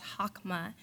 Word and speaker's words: chokmah: And as Sue Edwards even points out, wisdom in Hebrew is pronounced chokmah, which I love how Stuart actually defines that wisdom, chokmah, actually means our orientation with God.